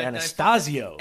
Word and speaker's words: Anastasio: This is Trey Antezito. – Nah, that'd be Anastasio.